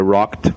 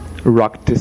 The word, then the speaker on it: rocked: I would also like to suggest a movie, which is called The Boat That Rocked. Rocked spells R-O-C-K-E-D. Some students, many of those at a beginning level, might find this word problematic, as the past E-D is pronounced here as a T, rocked.